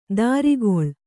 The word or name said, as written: ದರಿಗೊಳ್